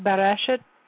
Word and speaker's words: Bereshit: But Bereshit, in the beginning, the book of Genesis.